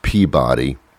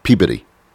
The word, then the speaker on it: Peabody: I know it looks like it's pronounced Peabody, because when I was younger, I used to watch Peabody and Sherman, which was a cartoon. So I always knew it as Peabody. But in Massachusetts, they pronounce everything incorrectly. So it's Peabody. I don't know why.